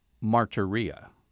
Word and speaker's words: martyria: I'm going to share it with you, just the Greek pronunciation, and you're going to guess what it means. The word for witness, for which they lost their lives and were slain, is martyria.